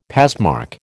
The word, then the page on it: passmark: American English